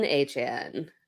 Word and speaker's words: Achane: Devon Achane.